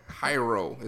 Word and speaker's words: gyro: Hyro is how they pronounce it. Hero. – Gyro. – No, because there's no G, so it's hero. Hero. Gyro. Euro. Euro. Y-R-O-H.